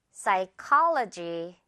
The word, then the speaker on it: psychology: P 빼고 psy라고만 발음된다는 거 듣고 따라해 주세요. psychology.